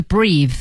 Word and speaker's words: breathe: 哦，对，做动词的时候是 breathe。 – 对，所以大家一定注意哈，有 e 的时候 breathe 才是动词，它没有 e 呢，它只能读成 breath，它是个名词儿啊、哦。